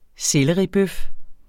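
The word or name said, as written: sellerbøf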